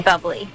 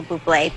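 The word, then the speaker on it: Bublé: You mean bubbly? – No, I mean Buble. – You're Michael Buble. That's a bubbly. – She's right, Michael Buble. It's pronounced Buble.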